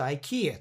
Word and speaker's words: IKEA: 長い単語だと二つあったりもするんですけども、そうするとそれ以外のとこ弱く読むっていうことになって、これだと IKEA っていう発音になるんですね。